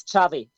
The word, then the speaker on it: Xavi: Yeah, it's Chavi. It's a Catalan name. – Gotcha. Okay. X-A-V-I for those wondering.